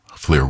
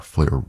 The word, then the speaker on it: Llyr: Fleur Williams, the pianist. His name is spelled L-L-Y-R. Fleur Williams.